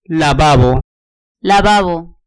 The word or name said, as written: lavabo